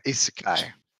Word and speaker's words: isekai: An isekai.